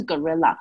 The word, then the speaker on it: guerrilla: 然后它有一个字，就是叫做 guerrilla war。它真的只有这个字，它发音就是 gorilla。嗯。